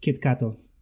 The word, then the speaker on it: Kit Kat: It's just Kit Kato.